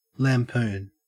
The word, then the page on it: lampoon: Australian English